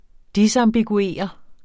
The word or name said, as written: disambiguere